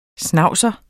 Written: schnauzer

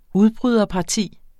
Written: udbryderparti

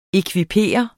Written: ekvipere

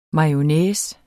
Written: mayonnaise

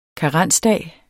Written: karensdag

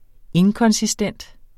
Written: inkonsistent

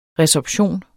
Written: resorption

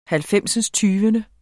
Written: halvfemsindstyvende

